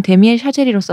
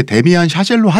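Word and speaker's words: Damien Chazelle: 아니 원래는 보통 이제 데미안 샤젤로 하자. 로 이제 합의를 봤는데 누가 본인이 합의해 준 거예요? – 아니 근데 그게 데미안 샤젤 원래 이런 식으로 발음을 하는 게 맞대요.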